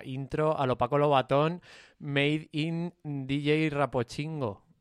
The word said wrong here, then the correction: Rapónchigo: ¿Lo he dicho bien? – No. – ¿No era Rapónchigo? – Rapónchigo.